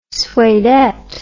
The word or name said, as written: suedette